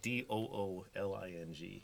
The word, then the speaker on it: Dooling: A part of the game is we have dueling and scalping. – My middle name is Dueling. – Really? – Not spelled like that. Dooling.